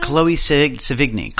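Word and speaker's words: Chloë Sevigny: Chloe Sevigny.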